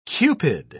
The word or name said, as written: cupid